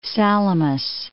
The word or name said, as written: Salamis